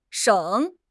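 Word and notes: sheng3: tone 3

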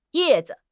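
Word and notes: ye4 zi5: tone 4; neutral tone

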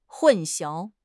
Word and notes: 混淆: HSK6

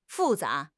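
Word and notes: fu4 za2: tones 4, 2

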